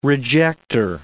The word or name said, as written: rejector